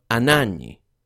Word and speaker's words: Anagni: His death occurred in the city of Anagni on the 1st of September. That's spelt A-N-A-G-N-I, because remember that in Italian, the G-N spelling is pronounced N.